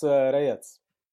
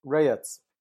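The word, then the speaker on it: Rajec: It's Reyes. – Reyes? – Reyes. So imagine Reyes? That the J is pronounced like a Y and the C is pronounced like TZ.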